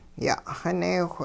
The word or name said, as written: ya'xineewh